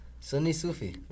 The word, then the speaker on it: Sunni Sufi: Are you a Sunni Sufi? – Sunni Sufi. – Did I say that right? – You did.